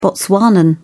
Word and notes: botswanan: British English

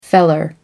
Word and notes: feller: American English

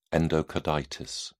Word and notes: endocarditis: British English

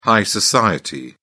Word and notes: high society: British English

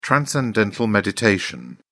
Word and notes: transcendental meditation: British English

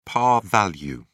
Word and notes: par value: British English

